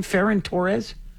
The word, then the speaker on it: Ferran Torres: How do you say his name? Ferran Torres? – Sounds right, yeah.